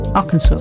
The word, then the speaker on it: Arkansas: It's Arkansas, it should be. – What? – Arkansas, the way it's spelt. Arkansas is spelt Arkansas.